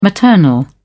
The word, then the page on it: maternal: British English